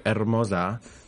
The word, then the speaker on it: Hermoza: That's G Hermosa. The H is silent because I'm Latin.